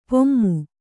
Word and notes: ಪೆಮ್ಮೆ: kannada

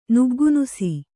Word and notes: ನುಗ್ಗುನುಸಿ: nuggu nusi

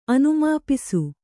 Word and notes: ಅನುಮಾಪಿಸು: anumāpisu

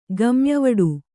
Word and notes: ಗಮ್ಯವಡು: kannada

